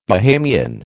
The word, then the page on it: Bahamian: American English